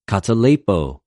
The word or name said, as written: καταλειπω